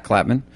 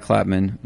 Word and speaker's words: Klapman: On the social medias, you can find me on Instagram, at fake Zach Clapman. And you can find me on Twitter, at Zach Clapman.